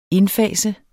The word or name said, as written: indfase